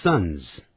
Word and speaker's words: sons: Mary has 2 sons. 玛丽有两个儿子。 Mary has 2 sons. 这个儿子的发音 sun 跟太阳的发音呢 sun sun sun 发音是完全一样的，不要念成 sun。